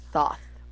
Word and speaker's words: Thoth: T-H-O-T-H. – You put S there. You put Thoth. – I have a lisp. – Thoth. – I have a lisp. – That's where your lisp would come in handy. You tried to overcorrect it, and you put an S. That is a lisp name.